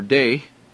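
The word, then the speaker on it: day (δεῖ): It's the Greek word DEI, or day, as it is pronounced.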